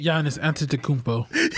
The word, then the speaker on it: Giannis Antetokounmpo: Giannis Antetokounmpo. – He was so tight. – I think I said it right, didn't I? – You were close. – Giannis Antetokounmpo? – Yeah, that's pretty close.